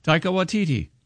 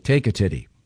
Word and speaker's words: Taika Waititi: Pronounce it again, Taika? – Taika Waititi. – Okay. I thought it was like take a titty.